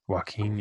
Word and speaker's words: Joaquin: Was it Joaquin Phoenix? Is that how you say his name? – Joaquin, yeah. – Joaquin.